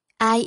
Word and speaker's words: I: Ai yêu anh? – Ai yêu tôi? – Tiếng Anh ai viết là y, đọc là ai, nghĩa là tôi, còn nghĩa là em, cũng nghĩa là tao.